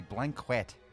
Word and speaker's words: blanquette: It's a blanquette of veal with prunes. Now, it's pronounced like this. – Blanquette. – Blanket. Pigs in blankets.